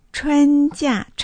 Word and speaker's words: 春假: 春假。春の発音は CHUN ですね。建設音、有機音、一世。春假。 J-I-A。